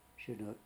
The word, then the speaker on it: Chinook: How would you pronounce that? – Chinook.